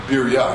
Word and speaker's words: Beria: Okay, let's move on to Simon Kuf, which is entitled to the cover, entitled Beria Afilu Be'elef Lo Batel. Okay, Beria Afilu Be'elef Lo Batel. Beria, probably more correctly pronounced Birya, I think, and those are Baladictor.